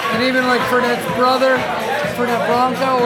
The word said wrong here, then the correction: Fernet Branca: Fernet Branca, Carpano, and Tinka.